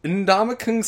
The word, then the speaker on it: Ndamukong: Ndamukong Suh. As long as you pronounce the N.